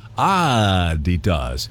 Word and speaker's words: Adidas: And then finally, Americans pronounce Adidas wrong. Now, it's supposed to be Adidas, Adidas, with a slight emphasis on the first syllable, Adidas, not Adidas like we all see it now.